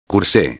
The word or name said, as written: curse